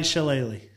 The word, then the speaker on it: Shyamalan: It's, how do you pronounce his name? M. Night Shyamalan or M. Night? – M. Night Shyamalan. – Yeah, we'll just call him Shyamalan. – Yeah, M. Night Shyamalan.